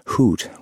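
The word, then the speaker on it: Hütte: This Utte, Ambrose was saying, he pronounced it hoot with a silent H, was carried up the Gelternhorn in one piece, suspended from a military helicopter.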